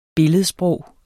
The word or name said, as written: billedsprog